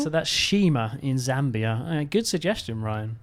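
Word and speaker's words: nshima: So this food is called shima, and it's spelt with a silent N on the start of it.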